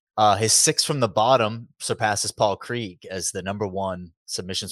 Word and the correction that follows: Craig: desire to pronounce Paul Craig correctly, you just went Krieg.